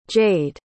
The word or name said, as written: jayde